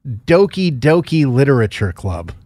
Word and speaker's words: Doki Doki Literature Club: So what is Doki Doki Literature Club? – You pronounced it right.